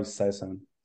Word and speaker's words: Saisan: Is it Saisan? How do you say that? – Yeah, English version is probably Saisan.